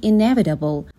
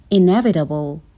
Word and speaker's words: Inevitable: Change is simply inevitable. یعنی تغییر صرفاً اتفاق میفته و حتمیه. به تلفظ این کلمه دقت کنین. Inevitable.